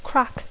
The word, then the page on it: crack: English